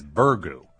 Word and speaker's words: burgoo: The correct pronunciation, as I have heard it, is burgoo.